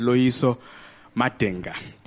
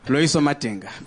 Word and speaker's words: Loyiso Madinga: Yes, yes, my name is Loyiso Madinga, which they mispronounce when I perform in New York. Simple African name, no sounds, no one has never heard in that word before. Loyiso Madinga, but they call me Loyiso Mandingo.